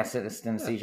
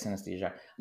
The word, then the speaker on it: synesthesia: It's synthesia. You have synthesthesia. – Yeah, synesthesia. – Yeah. Oh, yeah. Sorry. There you go. – I think that's how you say it, synesthesia. – Yeah, I think you're right.